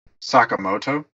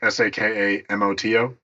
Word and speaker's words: Sakamoto: So, as Americans, we would say Sakamoto, mm-hmm. but like Sakamoto. But the way they had them pronounce it in the game is Sakamoto. So, like the inflection is in like the middle, and it sounds it's a weird complaint that I completely understand because it threw me off.